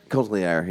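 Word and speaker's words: Cotillard: Michael Fassbender, Marianne, how do we say we pronounce it? – Coltier. – Yeah, there that's it what it is.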